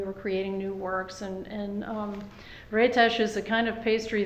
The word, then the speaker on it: Rétes: And then the series that you were doing at Ritz, is that how you pronounce it? – Rétes. – Oh, okay. I'm sorry. Thank you. – Rétes, it's a Hungarian pastry.